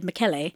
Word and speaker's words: Michele: He's Italian, so when you first see it, it looks like it's Michele DeMarco Lupo. But it makes sense when I heard people pronounce it and they said Michele. It makes sense because he's Italian.